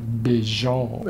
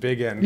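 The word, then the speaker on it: Bigend: which I think would probably be pronounced something like Bijon. – As you mentioned in that Wikipedia entry, yes. Yeah. But I figured it was, you gave the reader sanction to pronounce it Big End, yes.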